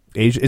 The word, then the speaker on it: Aja: Asia. Is that how you say it? – Yeah. – I never know how you say it, but yeah. – That's how they say it in the song.